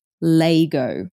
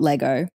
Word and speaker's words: Lego: So Trent and Josh, the young guys from South Australia, they mentioned that in South Australia, it's pronounced Laygo, not Lego. – While I am hesitant to talk down on someone's upbringing, it seems like growing up in South Australia is wild. Like, what's all that? Just making up your own pronunciation for things. Come on, guys. – I know. And I know, you know, around Australia, most of the states pronounce things slightly differently or might call a product something else somewhere. But Lego is like a universal thing. It's Lego.